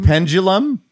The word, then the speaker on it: pendulum: P-E-N-D. – Yeah. P-E-N-D. – Spelled like it should be. – U-L-U-M. – There's no G? – There is no G in pendulum. – Well, she said pendulum. – Well, D-U makes a J sound.